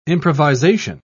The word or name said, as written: improvisation